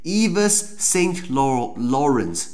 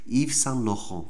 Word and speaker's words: Yves Saint Laurent: 那如果你用英文来念，对不对？就变成 Yves Saint Laurent，你就很很了。可是法文就是 Yves Saint Laurent。